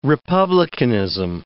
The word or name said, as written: republicanism